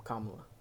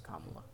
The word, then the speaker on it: Kamala: Yeah, people get mad if you mispronounce her name, so it's Kamala.